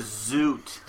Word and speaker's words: Zoot: His name was Zoot. – I know. – We know. – Mm-hmm. – Oh, boy. So, moving on. – Rhymes with toot.